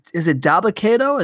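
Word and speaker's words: Dabba-Kato: Braun Strowman and is it Dabba Kato? Is that how you say it? – That is correct.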